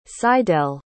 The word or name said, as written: Sydell